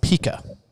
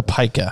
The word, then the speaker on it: pica: but Chris has pica, or pica, however you want to pronounce it, right? Yeah. P-I-C-A.